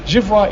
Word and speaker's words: vois: And the il and the je, if the je is je vois, V-O-I-S, and il, V-O-I-T, but given you don't pronounce the S or the T, je vois, il voit, they sound the same.